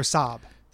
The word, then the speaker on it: Saab: Or Saab. That's how it's pronounced.